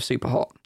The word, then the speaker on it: Superhot: Before you dive deeper down the rabbit hole, someone says you should pronounce Superhot the way it should be pronounced.